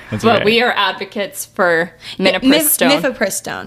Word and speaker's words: Mifepristone: No, Mifepristone. Am I saying it right? – Yeah. – Mifepristone. – Whatever. I don't know. – You guys know what we're talking about. – Mifepristone.